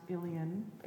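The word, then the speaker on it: Uilleann: We have Ilian pipes, which looks more like Ilian, but it's just pronounced Ilian.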